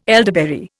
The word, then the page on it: elderberry: Indian